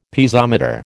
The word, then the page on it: Piezometer: American English